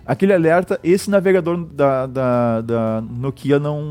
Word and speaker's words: Nokia: Nokia, Nokia. Acho que Nokia, né? É a pronúncia correta.